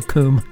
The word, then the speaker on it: COUM: I've also heard it referred to as Cum Transmissions or Com Transmissions, so I could be corrected on that, but it's spelt in capital letters C-O-U-M Transmissions, so I like to say Coom with an umlaut.